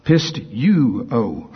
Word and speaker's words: pisteuo: According to this, you pronounce it pistou. No, pistuo. Pistuo. P-I-S-T-E-U-O. Let me do that again in case somebody's writing it down. P-I-S-T-E-U-O.